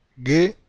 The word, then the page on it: gue: Spanish